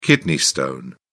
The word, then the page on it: kidney stone: British English